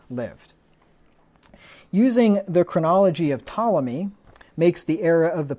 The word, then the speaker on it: Ptolemy: the main reason that people hold to this position is because they follow the chronology of a guy named Ptolemy. That's spelled with a P, P-T-O-L-E-M-Y, like the Ptolemies of Egypt, right, the ruling family in Egypt.